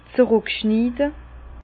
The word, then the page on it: z'ruck schniide: Bas-Rhin; Herrlisheim